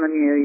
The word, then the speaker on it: Lemieux: So if you Google Karen Lemieux, you'll find me all kinds of different places and ways to access me.